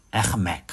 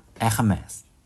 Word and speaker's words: Hermès: 同样的问题仍然发生在 Linux 上，也发生在 App 上，因为总是有几个比较傻逼的法国奢侈品牌是吗，也有个网站是专门教人读这种品牌的发音，比如说法国的读音，我们他虽然读 H，他可能不读这个，读 R 他可能读 H 是吗，说那个爱马仕大概读什么 Hermès，这样哈 Hermès，我也不知道都怎么读，哎呵们，大概就是有点吐痰的感觉，这个样子。